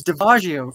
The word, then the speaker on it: Daviasio: It's Daviasio.